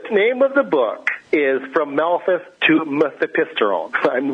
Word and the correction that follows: Mifepristone: which I now mispronounced. Mifepristone, which is an abortifacient pharmaceutical.